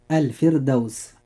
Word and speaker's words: الفردوس: كلمة الفردوس، الراء فيها مرققة لأنها ساكنة وقبلها كسر أصلي وليس بعدها حرف استعلاء، ولذلك ننطق هذه الكلمة مرققة هكذا. – الفردوس.